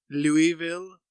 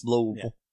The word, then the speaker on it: Louisville: If they say it Louisville, not from Kentucky. If you say it like you got a mouthful of marbles and liver, Louisville.